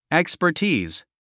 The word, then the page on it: expertise: èkspə r tíːz; エクスパティーズ